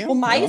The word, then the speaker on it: Stromae: Äh, Stromae. – Stromae. – Stromae, ja.